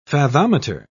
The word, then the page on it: fathometer: British English